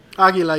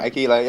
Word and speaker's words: Aguila: Um, in charge of CD Aguila, is that how you pronounce it? – I Aguila. believe so, but let's leave that to Peter. Aguila, yeah. Yeah, Aguila,